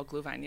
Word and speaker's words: Glühwein: Glühwein? – Oh, Glühwein, yeah.